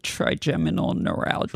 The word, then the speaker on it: Trigeminal neuralgia: I'm going to say this wrong. Trigeminal neuralgia? Is that close? – Trigeminal neuralgia.